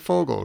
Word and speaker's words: Vogel: It always feels weird calling him Vogel. We're told that it's pronounced Vogel, but it seems very Vogel, strange to me. – Yeah, V's pronounced like an F in German.